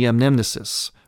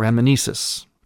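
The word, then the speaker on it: anamnesis: So what's happening now is we're introducing the amnesis, or amnesis, pronounced a couple of ways, which means a remembering.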